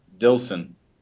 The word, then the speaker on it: Dilson: Dilson.